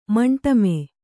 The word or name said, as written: ಮಂಟಮೆ